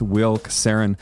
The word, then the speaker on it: Casarin: I am here with Will Casarin, who is the founder of Damus.